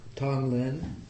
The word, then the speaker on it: Tonglen: Tong Lin. Mm-hmm. So it's spelled T-O-N-G-L-E-N.